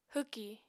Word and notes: hwki: apatani

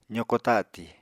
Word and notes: zoko tatì: apatani

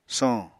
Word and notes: soñ: Apatani

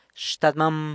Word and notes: xtadmam: grandfather (e-poss. only)

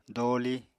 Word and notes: dooli: apatani

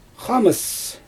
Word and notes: xaamɯs: Xyzyl

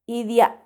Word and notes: idia: Macuiltianguis

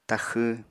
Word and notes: taxw: apatani

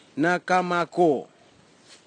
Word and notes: nakamako: kwamera; Tanna

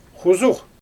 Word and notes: xuzux: Xyzyl

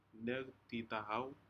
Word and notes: necditahou: Aneityum

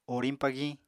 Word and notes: oriñ-pagiñ: Apatani

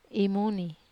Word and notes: mun: Wamesa

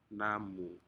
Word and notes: namu: Aneityum

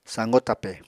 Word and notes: sañgo tape: Apatani

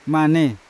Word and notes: mane: Yawa

